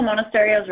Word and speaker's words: Monasterio: No, Monasterio's right.